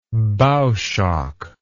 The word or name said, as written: bow shock